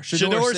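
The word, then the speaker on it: Shedeur: How do you pronounce it? Shader? – Shador Sanders.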